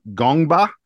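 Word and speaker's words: Ngongba: Gongba, I believe, is how his last name is pronounced.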